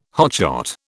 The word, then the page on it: hotshot: British English